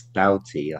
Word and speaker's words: Doughty: You've got these wing backs in Drama, and now is it Doughty? Do I pronounce it? – It's Doughty. Doughty.